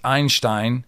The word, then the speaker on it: Einstein: As a German, I have to say that Jonathan was right in his pronunciation of Einstein, says one texter. The surname Einstein and Einstein, a stone, mean the same and are pronounced the same.